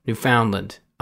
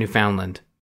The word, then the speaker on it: Newfoundland: Understand Newfoundland. Understand Newfoundland. Okay, here we go. – The first Earthlings to see the effects will be in Newfoundland, or Newfoundland. – Yeah, Newfoundland. Understand Newfoundland. Okay.